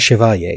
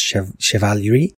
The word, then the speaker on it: chivalerie: There's an extra syllable in there, not making sense to me when I try to say it. Because it's not chevalier, it's chevalerie.